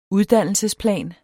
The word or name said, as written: uddannelsesplan